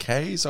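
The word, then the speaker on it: Kees: Keys, Voorhaus. How did you say that? – K's? Like kilometres, but K's, I believe.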